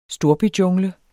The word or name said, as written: storbyjungle